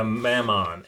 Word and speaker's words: Mammon: Memon. – Can you spell that? – M-O-M-M-O-N? Actually, hold on. – It should be M-E if it's Memon. – It's a Mammon. M-A-M-M-O-N. – Almost Mammon from the Bible.